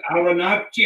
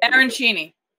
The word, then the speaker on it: Arancini: Aranachi? How do you pronounce that? – Arancini.